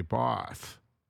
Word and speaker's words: Bath: Local residents armed with torches, buckets, and gloves patrol Charkham Lane in Bath, or as the Brits say, Bath.